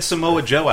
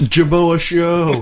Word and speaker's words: Samoa Joe: We had Samoa Joe out for an LWA show, and he said Samoa Joe's name wrong. – Ooh, yeah, that's... – Jamoa Show.